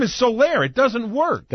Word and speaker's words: Soler: this new phenom of theirs called Solar, S-O-L-E-R, but it's pronounced Solaire. Okay, but in print, it looks like solar, S-O-L-E-R.